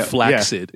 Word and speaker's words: flaccid: I'll go into that another day, but traditionally in in the languages that English is derived from, if you see a double C in anything, like succinct or flaccid, which most people are a little more familiar with, it's actually supposed to be pronounced as a K and an S sound afterward. – Okay, it's supposed to be succinct or flaccid. – Yeah. – But over time, people just become so lazy that just doing a double, like a S sound, is the acceptable sound. Anyway, nomenclature. It is a noun. And it is spelled N-O-M-E-N-C-L-A-T-U-R-E.